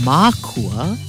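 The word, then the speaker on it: mākua: Makua, with a kahako or a makron over the first A, pronounced makua, is the plural of makua, or parents.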